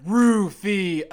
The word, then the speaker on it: Rufio: fucking B, three syllables, one word. Rufio.